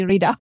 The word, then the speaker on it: Rena: Did I got it correctly, Rena? – You got it just right. So it's Rena Martin, like Rena Martin. Drinking a martini in Reno, Nevada.